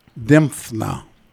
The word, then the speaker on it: Dymphna: And I must say, I have never heard of Saint Dymphna.